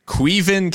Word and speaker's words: Caoimhin: yeah Caoimhin Kelleher which is how it's pronounced, um, even though it's like C A O I M um, H I N or whatever. It's Irish, like Gaelic shit.